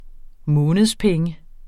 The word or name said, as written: månedspenge